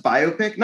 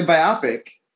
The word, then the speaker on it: biopic: Shouldn't it be pronounced biopic? Not we, but I mean, most people say biopic.